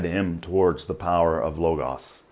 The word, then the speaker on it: Logos: He's from with a more in-depth discussion on the history of Logos and the Logos of history, but he pronounces it Logos because he's from Philadelphia. So I'll just honor his work by using his pronunciation.